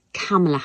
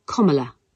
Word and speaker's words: Kamala: It is not Kamala Harris. It is not Kamala Harris. It is Kamala Harris. According to her own memoir in 2019, Kamala Harris says, it's like the punctuation mark, comma, Kamala.